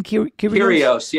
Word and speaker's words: Kyrgios: Kyrgios, is that how you say his name? – Kyrgios, yeah.